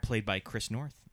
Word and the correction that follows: Noth: Noth. Yeah, Mr. Big. – Chris I always want to say North. – Is it pronounced Noth? – No idea. – Or Noth? I think it's Noth. I think people would say Chris Noth.